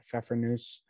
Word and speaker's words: Pfeffernuss: There's a silent P in the beginning there. Pfeffernuss.